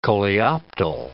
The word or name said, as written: coleoptile